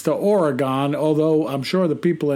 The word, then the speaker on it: Oregon: And I'm going to tell you how to pronounce it. Oregon. Say it. Say it with me. Oregon.